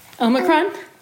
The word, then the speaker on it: Omicron: I think, am I saying it wrong? No, I've said it too many times. Omicron.